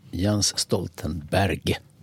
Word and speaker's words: Jens Stoltenberg: Jens Stoltenberg, så uttalas han på norska.